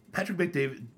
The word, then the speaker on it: Patrick Bet David: He's a guy, he's like, Patrick Bet David, am I saying it right? – Yeah.